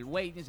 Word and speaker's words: waiting: El Waiting se pronuncia con G, ¿sabían? – para que aprendas a pronunciar.